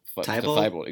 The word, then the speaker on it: Thybulle: Thybulle, exactly.